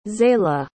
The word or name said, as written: xaila